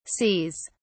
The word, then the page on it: sees: British English